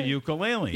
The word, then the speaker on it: ukulele: A very overweight man, like strumming on that little, is a ukulele? Is that how you say it? – It's a ukulele.